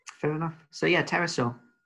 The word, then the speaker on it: pterosaur: My main worry with that one is, do you say pterosaur? Or do you, like any good housemate in the middle of the night, go with the silent P? – Silent P.